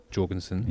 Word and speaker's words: Jorgensen: But as we've been rightly corrected with Jorgensen. – Yeah. – The hard J being the correct one.